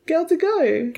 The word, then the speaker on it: Gal Gadot: You can't even say her name right. – Gal Gadot. – Gal Gadot. – Oh, Gadot.